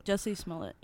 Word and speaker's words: Jussie Smollett: Jussie Smollett, is that how you say it? – Jussie Smollett, yeah.